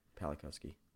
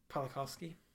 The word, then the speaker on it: Pawlikowski: during a post production, and then Paolo Polinsky for Cold War. – Pawlikowski. – Pawlikowski.